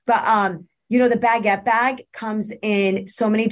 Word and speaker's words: baguette: Oh, um, you know, I think I've got everything I've coveted, but it was mostly the Fendi stuff, the bank, the baguette bags. Did I just say that right? – Baguette yeah, baguette, baguette, like the baguette bread. Bag. – I almost said banquette, and I'm like, no, that's not right.